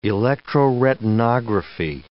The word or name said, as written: electroretinography